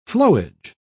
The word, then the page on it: flowage: British English